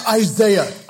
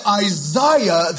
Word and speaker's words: Isaiah: The pronunciation of Isaiah is better said Isaiah than Isaiah.